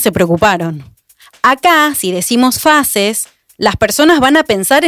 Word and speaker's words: Faces: F-A-C-E-S. Una de ellas consultó. Ah, entonces vamos a pronunciarla en inglés decirlo como faces? ¿Es para hacer alguna alusión a distintos rostros y diversidad? No está mal. Las brasileñas respondieron, no, es faces, que en portugués también quiere decir rostros.